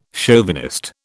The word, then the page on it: chauvinist: British English